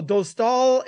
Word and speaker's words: Dostal: and they have Dostal. I think I said it right? I think for the first time in a year, I've said his name right on the first try. So Dostal is there.